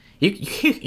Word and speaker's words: Tyrion: Tyrion. I said it right? – Yes, okay. Tyrion.